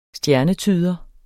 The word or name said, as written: STJERNETYDEREN